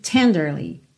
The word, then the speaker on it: tenderly: E aqui, essa palavra normalmente tem a sílaba tônica na primeira sílaba, ok? Tenderly, tenderly.